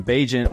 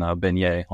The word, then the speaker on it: Bagent: Tyson Bagent, welcome to the quarterback room. I'm saying it right. Tyson Bagent, right? – Perfect.